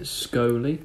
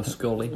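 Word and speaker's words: skollie: And you pronounce it scoly or scolly. – Scolly, like trolley. – Yeah, or Dolly, as it says literally right on the back.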